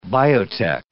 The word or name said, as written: biotech